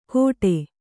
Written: ಘೋಡೆ

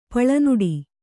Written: ಪೊಲೆನುಡಿ

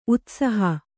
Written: ಉತ್ಸಾಹ

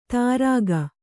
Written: ತಾರಾಗ

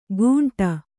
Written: ಗೂಂಟ

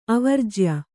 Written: ಅವರ್ಜ್ಯ

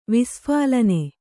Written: ವಿಸ್ಫಾಲನೆ

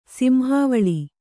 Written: ಸಿಂಹಾವಳಿ

